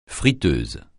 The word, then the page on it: friteuse: francais